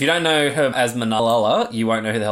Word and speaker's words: Malala: Malal. Is that a T? Malal. – Nah, they're all L. It's Malala.